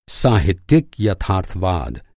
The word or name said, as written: यथार्थवाद